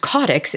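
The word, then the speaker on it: caudex: There are so many different ways to refer to caudix plants, which I've recently learned caudix is the correct way to pronounce it.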